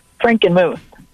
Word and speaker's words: Frankenmuth: Frankenmuth. – Frankenmuth. Frankenmuth. As in Frankenstein. – Yes. – Frankenmuth. There you go. M-W-S.